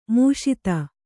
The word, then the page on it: ಮುಷಿತ: kannada